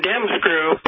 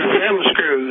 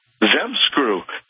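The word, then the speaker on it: ZZEM Screw: I'm sure you're comfortable saying Dem Screw, but this is Zem Screw. Z-Z-E-M. – Zem Screw. Two Z's, right? – Yeah, just like the noise it makes when it goes in. – Zem Screw.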